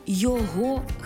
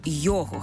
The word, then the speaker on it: його: Коли прийменник поєднується не із займенником, а з іншою частиною мови, від якої цей займенник залежить, то наголос не змінюється. Тобто займенник зберігає свій наголос на другому складі, навіть якщо й прийменник присутній. Наприклад, до його хати, а не до його хати.